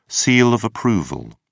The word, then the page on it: seal of approval: British English